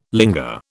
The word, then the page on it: linga: British English